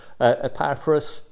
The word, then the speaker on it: Epaphras: I've always called him Epaphras, but of course it's a short E. Epaphras, there's Laodicea, there's Hierapolis, and there's Nympha, which is fairly obvious, isn't it?